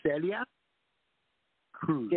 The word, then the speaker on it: Celia Cruz: Celia Cruz.